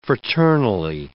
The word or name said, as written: fraternally